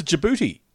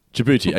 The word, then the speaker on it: Djibouti: Well, it's a place, DJBOUTI. – Oh, it's a Djibouti. – Djibouti.